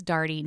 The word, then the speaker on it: Daugherty: My last name is Darty. No one knows how to say it or spell it. So I always tell people, I'm Christy Darty and I like to party.